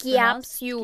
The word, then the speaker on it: Capsul: Capsul, like I think that's how it's Capsul. – Pronounced. – Capsule.